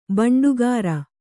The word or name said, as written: ಬಂಡಿಕಾರ